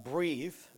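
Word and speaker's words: breathe (בְּרִית): Quote, at its core, and I think this word here is pronounced breathe, just like breathe.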